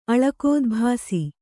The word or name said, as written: ಅಳಕೋದ್ಭಾಸಿ